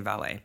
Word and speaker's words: valet: I know why you want to say valet, but it's valet. – It's valet. – It rhymes with palette.